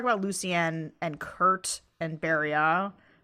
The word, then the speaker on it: Beria: Berio. – Berio. Oh yeah, somebody sent me this on Twitter. That's like O at the end. – Yeah, the French OT is just pronounced like an O.